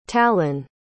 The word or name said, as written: talen